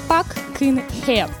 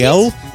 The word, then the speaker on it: Пак Кын Хэ: Пак Кын Хэ. Вот так, больше я не буду это произносить. – Хэл? – Хэ, просто как.